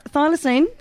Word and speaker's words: Thylacine: Now, Thylacine, is this how you say it? – Yes.